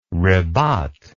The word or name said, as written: ribat